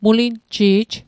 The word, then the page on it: mulinji'j: Mi'gmaq